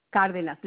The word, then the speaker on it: Cardenas: Yes, this is Ana Cardenas. – I'm sorry, what's your last name? – Cardenas.